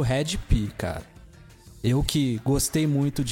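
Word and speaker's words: Hed PE: Waps, é o Red P. – Essa banda se escreve estranho o nome dela, é H-E-D-P-E, né? – É Planet Earth, né?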